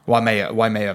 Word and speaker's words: Waimea: Waimea Valley, yes.